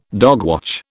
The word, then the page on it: dogwatch: British English